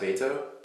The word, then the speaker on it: Beto: I think it's technically pronounced Beto. – Beto? – Beto, it's Spanish.